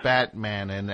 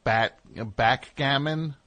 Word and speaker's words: backgammon: Batmanin, and I'm assuming that's backgammon? – Wait, what's it called? – Backgammon. – Spell it. Like B-A-C-K? – B-A-C-K-G-A-M-M-O-N.